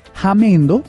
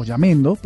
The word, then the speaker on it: Jamendo: El primero se llama Jamendo o Yamendo, pero se escribe con J.